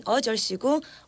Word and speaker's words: 어절 쉬고: In 어절 쉬고, 어 should be stressed. – The first syllable.